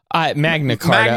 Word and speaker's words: Magna Carta: Did I say that right? What is it? – Magna Carta. – Magna Carta. – I don't know, thank you. – I don't care. – I knew that didn't feel right.